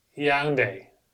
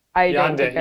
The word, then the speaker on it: Yaoundé: I looked up how to pronounce it, and now I think I've forgotten, but Yaoundé. – Okay. Yaoundé. – Y-A-O-U-N-D-E.